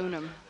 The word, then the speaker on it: unum: It's Unum.